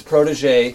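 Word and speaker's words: protégé: Some of the ones not chosen are things like the Protigi. – I love the Protigi. – Yeah. The Legacy. I know it's Protege. – You whatever. – Think the fastest on the wrong syllable.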